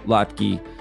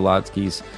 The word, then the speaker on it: latke: There's a there's a really great classic potato latke uh, with homemade applesauce recipe on there. What's a latke? I hope I'm saying that right. latke. Yeah. latke, latke, latke, latke.